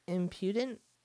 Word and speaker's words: impudent: Impotent cloud. That's good. – No, impudent.